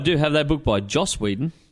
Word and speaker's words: Joss: So many people call him Josh. – Yeah. – And it's not. It's Joss. J-O-S-S.